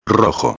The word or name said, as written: rojo